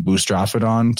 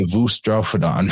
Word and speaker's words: boustrophedon: And so in this really comes from the Greeks more than anywhere, that in the modern Greek speaker, their letter beta, they pronounce as a V. So like where we were saying boustrophedon to talk about right to left writing instead of left to right writing, they would actually say voustrophedon, voustrophedon.